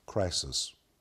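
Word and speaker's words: Crisis (κρίσις): And when he executes judgment, the word judgment is a very specific Greek word that's pronounced like this. Are you ready? Crisis. And it's where we get the word crisis.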